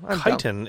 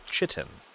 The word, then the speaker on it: chitin: The hard shell, also mispronounced as chitin. – Ah. – Much like lichen is also possibly lichen sometimes.